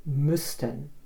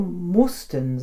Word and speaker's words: Müssten: Müssten wird mit Umlaut geschrieben und dann also als Ü ausgesprochen. Müssten. Wenn Sie hier mussten sagen, dann benutzen Sie die Vergangenheitsform und das kann wirklich zu Missverständnissen führen.